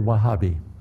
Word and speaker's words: Wahhabi: Say the word Wahhabi. It's spelled W-A-H-H-A-B-I.